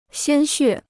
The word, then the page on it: 鲜血: xiān xuè